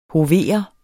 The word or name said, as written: hovere